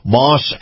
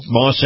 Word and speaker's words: Maus: Now, Acura, Moss Acura, and Moss is spelled M-A-U-S, competes very favorably with other luxury brands.